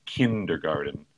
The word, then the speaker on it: kindergarten: in the word for the school, we say kindergarten. And we Kindergarten. – it sounds like garden with a D, but we spell it with a T, actually.